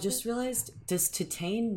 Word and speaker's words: Titane: I like Titane. I think that's the correct way of saying it.